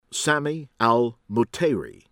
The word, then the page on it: AL-FADHLI, MOHSAN: MOH- san al FAHD- (heh)-lee